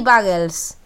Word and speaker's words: bagels: ¿Está bien pronunciado? Sí, bagels. – Sí, bagels. – Bagels. Bagels. Ahí sí, está. – Así se pronuncia.